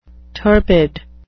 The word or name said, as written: turbid